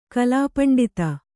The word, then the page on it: ಕಲಾಪಂಡಿತ: Kannada